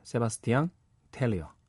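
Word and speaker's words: Sébastien Tellier: 세바스티앙 텔리어 라 리투넬 이렇게 해야지 정확한 거죠.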